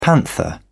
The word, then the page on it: panther: British English